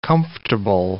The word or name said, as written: comfortable